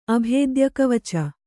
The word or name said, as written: ಅಭೇದ್ಯಕವಚ